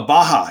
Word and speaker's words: Baja: Oh, Baja. Yeah. – Baja. – The J is silent. There you go. Baja. Yeah, that's it.